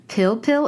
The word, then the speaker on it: pil pil: So the other night, I made your recipe for scallops and pil pil.